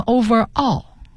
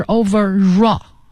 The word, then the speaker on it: overall: 嗯，再往下一个词是 overall 呢，它是一个组合在一起的， over 和 all 放在一起，有同学念成 overall，那不对，是 overall 啊，它要连在一起读，所以 r 结尾的词加元音开头的这种连读是 raw 啊，所以你会听到 overall 是全部的、总的这个意思，形容词。